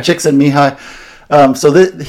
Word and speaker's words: Csikszentmihalyi: This guy who wrote the sort of most famous book about this called Flow, his name is, it's a word that starts with C that C is unpronounceable, but you say it, Csikszentmihalyi.